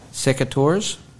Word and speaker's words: Secateurs: Secateurs. – Mm-hmm. – Is that how you pronounce that? – Yeah. Yeah.